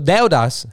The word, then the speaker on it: deudas: Think of E and U like almost in the same like bubble. Like Deudas. Deudas. Something like that, yeah.